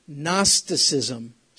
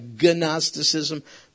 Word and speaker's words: Gnosticism: Gnosticism. Starts with a G, like Gnosticism, but the G is silent.